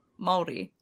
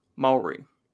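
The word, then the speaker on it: Māori: Can you tell me yeah. how to say it properly? – Maori. – Maori. – Yeah, you gotta kind of gotta roll, you gotta roll R, your R, kind of like a D.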